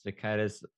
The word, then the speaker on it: Jakaitis: Jakaitis.